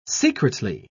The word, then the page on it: secretly: British English